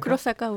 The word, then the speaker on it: Kurosaka: parce que je ne sais pas si c'est Kurosaka ou Konosaka. – Ah, Kurosaka, oui. Keita Kurosaka, oui.